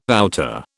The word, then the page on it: outer: British English